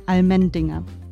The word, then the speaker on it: Allmendinger: Das ist der Sound of Feierabend im Hause Almendinger. Almendinger ist richtig ausgesprochen, oder?